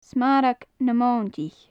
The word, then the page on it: smarag na mointich: gaelic